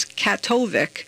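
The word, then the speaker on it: Kaktovik: That work will include improvements to facilities located outside the refuge in the Inupiat Village and I looked these up on how to pronounce these villages, and here we go. So one of the villages is Inupiat, and the other one is Katovic. – Sounds right to me.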